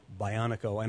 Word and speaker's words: Bionico: Bionico. And no, I'm not mispronouncing that. It is supposed to be pronounced in the anglicized fashion.